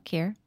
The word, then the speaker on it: Kirsten: So I asked her how to pronounce it and she said it rhymes with fear. – Oh, okay. So Kier.